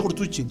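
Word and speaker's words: Contursi: Cortucci. – ¿Se pronuncia Cortucci? – Sí.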